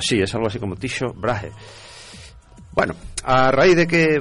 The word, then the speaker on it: Tycho Brahe: Hemos estado viendo cómo se pronunciaba en alemán y en latín. – Sí. – Y es Tycho Brahe.